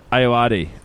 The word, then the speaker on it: Ayoade: That's Richard Iota. How do you say it? – Ayoade.